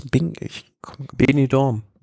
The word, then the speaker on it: Benidorm: Benidorm. – Benidorm.